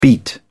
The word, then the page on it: beat down: British English